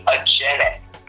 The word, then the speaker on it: Ajene: Neither. It's actually Ajene.